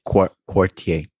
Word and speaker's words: Quartier: think I said that wrong. – I think it's quartier. – Quartier. – Quartier. – Yeah, so it's spelled like quarter. – Quarter. – Yeah, basically with an I instead, quartier. – I instead of just the E.